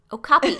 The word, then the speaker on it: okapi: It is pronounced O-cop-E. I found a pronunciation guide that's literally spelled out O-H and then the hyphen, and in all capitals it says cop, like C-O-P, and then hyphen E-E. O-copy.